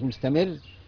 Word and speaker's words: مستمر: بس نراعي ان احنا بننطق كلمة مستمر نشدد الايه؟ الراء. مستمر، مستقر، نشدد ايه؟ الراء.